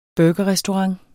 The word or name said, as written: burgerrestaurant